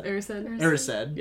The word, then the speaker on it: Erised: Erised.